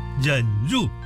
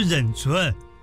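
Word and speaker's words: 忍辱: 这个字念作入，忍入，不是忍存，被你打败了。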